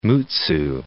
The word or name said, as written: Mutsu Bay